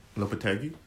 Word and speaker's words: Lopetegui: and why am I spacing on Lopetegui? Am I pronouncing that right? – Lopetegui? – Lopetegui? – Yeah.